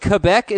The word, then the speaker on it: Quebec: It is, you could say it either way, but Quebec is very appropriate pronunciation of the word, Dave.